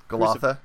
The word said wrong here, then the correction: Golgotha: Yeah, did I mispronounce it? – Golgotha. – Golgotha.